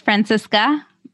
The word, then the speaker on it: Francesca: It's pronounced Francesca, but totally okay.